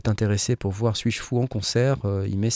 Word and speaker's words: S8JFOU: Et si, et vous sur, Google ou, votre moteur de recherche, c'est S8JFOU. S8JFOU et ça se prononce Suis-je fou C'est cool.